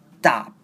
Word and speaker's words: stop: 那最后的 top 的声音呢，我们不会念 top，而会把 t 变成 d 的声音 stop。